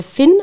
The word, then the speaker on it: phin: Und zwar heißt es der Finn, also mit P-H-I-N wird der ausgesprochen.